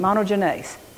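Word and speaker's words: monogenes (μονογενής): That word for begotten there is monogenes. I probably just pronounced that wrong. Monogenes. That's how you pronounce it. Monogenes.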